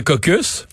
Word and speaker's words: caucus: Euh, Jean Lapierre disait parfois que au parlement il y a les caucus. Ouais. Mais dans certains cas, on prononce pas le S.